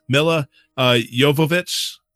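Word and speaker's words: Milla Jovovich: Mila Jovovich, I believe.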